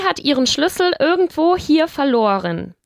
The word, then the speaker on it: verloren: Ich habe es irgendwo verloren, ¿vale? Tenemos aquí el participio de verlieren, ¿vale? – Que uh es verloren y que pronunciamos con una O larga, verloren.